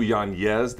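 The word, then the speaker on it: Yáñez: His name actually is pronounced. This is true. Yanez. The N has an N-Y, but if we want to say Yanez, it's fine.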